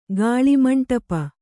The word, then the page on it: ಗಾಳಿಮಂಟಪ: Kannada